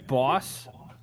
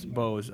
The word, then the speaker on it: Bose: Yeah, is he saying boss? – Yeah, like B-O-S-E. It's like...